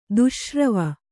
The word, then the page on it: ದುಶ್ಶ್ರವ: kannada